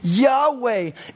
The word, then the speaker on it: Yahweh: The divine name, those four letters, Yod, He, Vav, He, which we now pronounce as Yahweh.